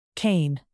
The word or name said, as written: cane